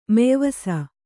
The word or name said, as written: ಮೆಯ್ವಸ